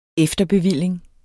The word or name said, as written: efterbevilling